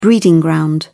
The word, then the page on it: breeding ground: British English